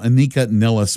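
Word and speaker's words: Anika Nilles: That is how you say her name, Anika Nellis.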